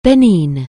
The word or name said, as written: Benin